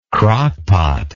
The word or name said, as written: crockpot